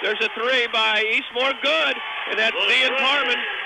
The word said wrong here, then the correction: Parham: That makes it 10-4. Zion Parham. And we've mispronounced some. We apologize.